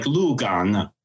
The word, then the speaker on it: Lugang: And I loved going to places like Lugang. – Lugang. – Am I saying that right? – Yeah.